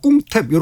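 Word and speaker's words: 꿍탭 (กรุงเทพ): 그래서 크롱이라고도 안 그러고 꿍탭 이렇게 발음하는 경우가 많습니다.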